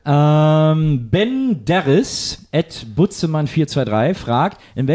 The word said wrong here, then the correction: Benderis: Ich glaube, es ist Benderis. Da spricht man sehr aus. – Ach stimmt, Benderis, stimmt. Ja.